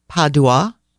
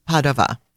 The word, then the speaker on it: Padova: Allora, let's chat happy things and about the fabulous little city of Padova, which Americans pronounce Padua or Padua, but in Italian, Padova.